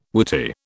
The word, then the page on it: whity: British English